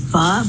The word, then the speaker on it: pho: I am reviewing McDonald's pho burger. All right, let's see. – Okay, so she did. She pronounced it right.